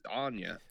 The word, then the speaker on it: Anya: There's a Mad Max spinoff coming with the wonderful Anna Taylor-Joy. Is that how you say it? Anna Taylor? Anna? Anna? – Like, I've been saying Anna, but it looks like it's Anya.